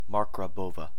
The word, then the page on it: Marggrabowa: German